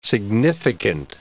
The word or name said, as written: significant